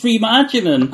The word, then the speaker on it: Freema Agyeman: No, and Nomi and Amita, because that's her girlfriend, played by, I'm going to butcher this, Freema Agyeman. – Agyeman. – Is this good enough? – Doctor Who. That's why I fancy her. That's why I fancy her. It's Freema Agyeman.